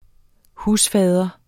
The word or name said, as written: husfar